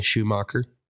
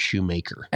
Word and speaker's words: Shoemaker: Schumacher. – Shoemaker. – I've never heard anybody's name pronounced Shoemaker. – S-H-O-E-M-A-K-E-R. – Schumacher. – No, it's Shoemaker. Just like the guy that fitted you for your PXGs.